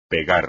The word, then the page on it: pegar: espagnol